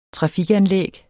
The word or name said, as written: trafikanlæg